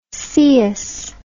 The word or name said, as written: coeus